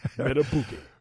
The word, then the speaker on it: Madubuike: Madubuike.